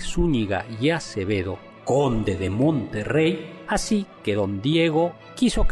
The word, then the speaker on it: Monterrey: Y por supuesto, en California existe un Monterrey, a mí me gusta pronunciarlo así, con doble R, porque aquello era territorio mexicano.